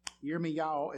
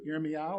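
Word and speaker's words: Yirmiyahu (ירמיהו): Yirmiyahu is the pronunciation of Jeremiah in Hebrew. Yirmiyahu or Jeremiah 17, verse 1.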